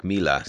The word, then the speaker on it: Mila: And I came across your project, Mila. Is that how you pronounce it? – Yes.